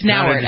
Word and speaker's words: Nauert: It's Nauert, actually. You're talking about UN? Yeah, Heather Nauert.